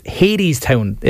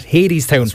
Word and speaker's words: Hadestown: And I need to apologise to you, by the way, because it's pronounced Hadestown. Am I right with that? Hadestown. – That's right. – Hadestown. But it's spelled H-E-D-E-S-T-O-W-N, so apologies for that.